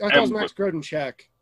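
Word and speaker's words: Grodénchik: Max Grodenkirk. – Yes, is that how you pronounce it? – Max Grodencheck. – Grodencheck.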